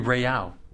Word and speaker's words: Breau: I hope I'm pronouncing her name correctly. – B-R-E-A-U, right? – Right. Bro. And for years... – Or it could be Breyau. – Breyau, yeah, it could be. Breyau. Yeah, I like Breyau.